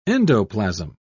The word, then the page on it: endoplasm: British English